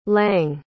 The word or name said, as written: lange